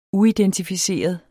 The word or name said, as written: uidentificeret